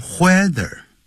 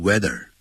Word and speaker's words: whether: 这个不要念成 whether, 要念成 weather。 H 是不发音的。